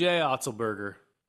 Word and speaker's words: Otzelberger: But old TJO, how you pronounce his name? You're better than me at all that. – TJ Otzelberger. – Otzelberger.